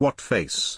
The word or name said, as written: wutface